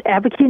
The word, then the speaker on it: Abiquiu: Abiquiu, New Mexico. Am I saying that right? – That's right, Abiquiu, New Mexico.